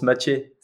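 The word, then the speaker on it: Maciej: Euh, Massiège, c'est, euh, ça se prononce Mathieu. C'est un nom polonais et c'est un jeune que j'entraîne euh, à Lausanne.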